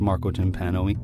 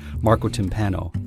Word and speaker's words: Marco Timpano: I'm your host, Marco Timpano. I can't even say my name right. Marco Timpano.